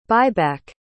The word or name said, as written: buyback